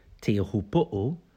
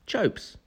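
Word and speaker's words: Teahupo'o: If you said Tihuputu, correct. If you said Chopes, Chopu, also correct, kind of.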